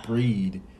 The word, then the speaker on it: Brigid: So, the final god, well, deity that we have for Celtic is Brigid, or Breed, as it's pronounced sometimes.